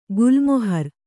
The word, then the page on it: ಗುಲ್ಮಹರ್: gulmahar